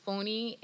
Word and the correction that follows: Fonny: is Is that how you pronounce his name? – Fonny. – Fonny. – Yeah.